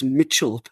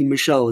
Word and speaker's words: Michel: But let's just pause and let's go back to do you pronounce it Michel? I thought it was Lewis Mitchell, but I realized that the T is missing. So yes, it must be Michel. Is that right?